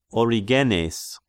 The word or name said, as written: Origenes